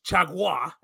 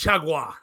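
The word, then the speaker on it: Chargois: They gave him the win because it would have been JT Chagua, as that's how it's pronounced.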